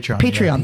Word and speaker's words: Patreon: Patreon? Is that how you pronounce that? – Patreon.